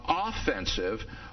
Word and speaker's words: offensive: So let me talk a little bit first about offensive or positive apologetics. Now, notice that it's critical that you get the emphasis on the right syllable. We're talking here about offensive apologetics, not offensive apologetics.